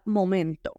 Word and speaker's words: moment: Lo único que vamos a hacer es comernos la O. Y le metemos flow, moment. Y que suene la T final.